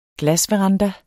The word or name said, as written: glasveranda